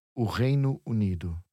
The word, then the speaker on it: o Reino Unido: So the United Kingdom is o Reino Unido. Three words there.